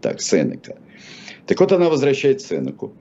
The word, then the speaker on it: Сенека: Давайте будем, я буду называть Сенека, вы как хотите, товарищи. Даже Википедия ставит два ударения. Да. А грамота настаивает на, а, на ударении на второй слог, а, привлекая мощного свидетеля Александра Сергеевича Пушкина.